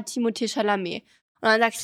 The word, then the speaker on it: Timothée Chalamet: Aber in Frankreich sagen die alle Timothée Chalamet, weil das ist ja ein französischer Name und das ist ja nicht TH, sondern es ist Timothée und dann Timothée, da ist dann danach ein H. Aber in Frankreich tut man ja diese H ist ja nicht aussprechen. – Ah ja. – Und für mich heißt er wirklich Timothée Chalamet und ich bin mir ziemlich sicher, dass es auch so eigentlich ausgesprochen wird. Nur Amerikaner sprechen es halt nicht so aus, ah, ja logisch.